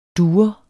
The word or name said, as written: dure